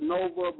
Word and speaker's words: Novah: You can find me on Facebook at Adisa Nova Most Win Hearts. Nova is spelled N-O-V-A-H.